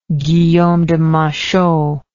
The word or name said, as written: Guillaume de Machaut